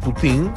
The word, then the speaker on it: Poutine: Esto se escribe Poutine, ¿ah? eh, francés, Putin.